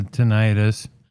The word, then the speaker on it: tinnitus: I had been pronouncing it wrong. It's a tinnitus.